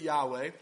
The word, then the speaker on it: Yahweh: So, because it's so important and so special to God's people, we actually don't um, perfectly know how to pronounce the name Yahweh. We can have confidence that you do pronounce it Yahweh, and yet we don't know for sure. And that's because the Hebrew Bible was actually written with consonants only.